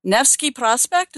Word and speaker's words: Nevsky Prospect: I remember going down, it's Nevsky Prospect, is that how it's pronounced? – Sounds right.